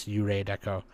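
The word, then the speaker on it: Yurei Deco: Zero is pronounced like Ray in Japanese, Yurei Deko.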